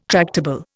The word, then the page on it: tractable: Indian